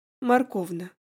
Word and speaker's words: Морковна: По имени-отчеству, но последнее произносил на своеобразный манер, с ударением на букву О. Морковна.